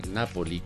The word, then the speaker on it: Napoli: Este tema lo abordamos a propósito que el Nápoles, Napoli, como se pronuncia en italiano, está a punto de llegar al campeonato.